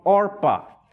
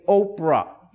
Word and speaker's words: Orpah: It's my understanding that the name Oprah derives her name from Orpah. They kept mispronouncing her name. Instead of saying Orpah, they kept saying Oprah.